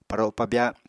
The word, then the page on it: paro pabya: Apatani